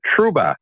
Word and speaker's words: Trouba: Jacob Trouba, if I'm saying it right. – You are correct.